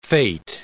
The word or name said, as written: fate